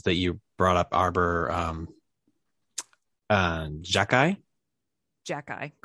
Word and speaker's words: Xhekaj: The reason why I'm confused with how you pronounce this is, if you aren't familiar with this prospect, his last name is spelled X-H-E-K-A-J. Phonetically, that's a nightmare.